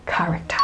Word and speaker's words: character: but it is actually the k sound or the k sound, okay? So you say k, but the stress is not on the second syllable, it is on the first syllable. And hear how it goes. Character.